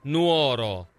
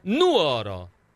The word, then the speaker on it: Nuoro: Comunque al buon e bravo Pasquale dica che non è Nuoro, ma Nuoro. La mia ragazza è sarda, mi concede di ascoltarvi a tutte le ore, ma se vi sentisse pronunciare Nuoro invece di Nuoro, il nostro idillio finirebbe.